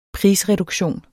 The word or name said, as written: prisreduktion